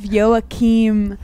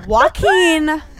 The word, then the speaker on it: Joaquin: Oh, I was thinking of Joakim Noah. He's a tennis player. I apologize. I fucked it up immediately. I'm so bad at front page news. Okay. Joaquin Phoenix.